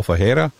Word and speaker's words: Ferreira: And if I guess we're pronouncing it in Portuguese, it'd be Paul Ferreira.